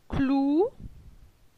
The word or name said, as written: Clou